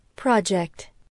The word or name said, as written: project